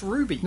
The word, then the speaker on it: RWBY: Is it pronounced Ruby? – It is indeed pronounced Ruby.